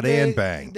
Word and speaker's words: Anbang: And bang, A-N-B-A-N-G, Anbang. It's spelled just as it sounds, okay? Come on, it's easy.